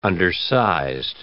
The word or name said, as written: undersized